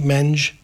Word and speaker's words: mange: Is it pronounced mangy? – Mange. Wombat mange.